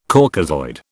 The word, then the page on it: Caucasoid: British English